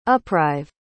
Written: uprive

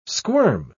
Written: squirm

